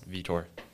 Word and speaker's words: Vietor: And also, my last name's pronounced Vietor.